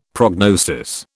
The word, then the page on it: prognosis: British English